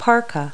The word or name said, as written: parka